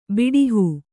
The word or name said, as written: ಬಿಡಿಹೂ